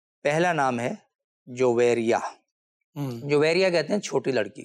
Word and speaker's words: جویریا: اچھا، اس کا پرونسیشن لوگ صحیح نہیں کرتے، جویریا پڑھتے ہیں، جویریا کہتے ہیں، جویریا. – ہاں، تو یہ لفظ ہے جویریا. – صحیح، تلفظ اس کا یہ ہے.